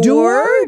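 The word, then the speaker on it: Doerr: en anglais, ce serait Anthony Doer. The guy is a doer.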